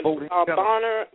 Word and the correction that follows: Boehner: Did I pronounce his name right? – Yeah, the Speaker of the House, Speaker of the House, Boehner, John Boehner. – Boehner. Thank you for correcting me.